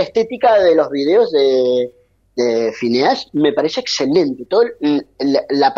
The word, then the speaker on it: Phineas: Y muy bien que es verdad, la pronunciación correcta es Phineas, en inglés es Phineas. Pero aquí cuando uno lo lee dice Phineas.